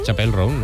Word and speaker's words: Chappell Roan: Chappell Roan, ¿no? – Ella misma nos explica cómo se pronuncia.